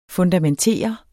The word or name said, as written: fundamentere